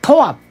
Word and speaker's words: 永遠: 永遠と書いて日本ではとわと読みます、